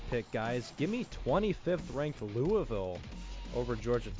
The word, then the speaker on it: Louisville: Louisville. That's how you say it.